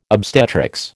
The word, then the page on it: obstetrics: American English